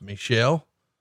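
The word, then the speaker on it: Michel: He breaks in the business when his brother, which I would think when you look at it would look like it would be pronounced Michael, but in fact, I believe it's pronounced Michelle, called and told him a wrestler had been injured and a replacement was needed.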